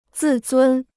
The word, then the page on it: 自尊: zì zūn